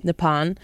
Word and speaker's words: Nippon: Did I say that right? – Nippon? – Nippon.